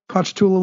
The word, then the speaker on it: Ponchatoula: Ponchatoula. Did I pronounce that right? Ponchatoula, Louisiana? – I think you nailed it.